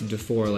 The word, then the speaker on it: DeFoor: Defour. Is it Defour? No, it's DeFoor, like the number.